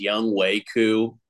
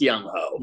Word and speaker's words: Younghoe Koo: They say that the way to pronounce his name is Younghoe Koo. It's Younghoe.